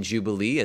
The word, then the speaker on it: jubilee: And if you pronounce the J in Jubilee as most other languages do, it sounds like Jubilee, as it is in fact derived from Yobel.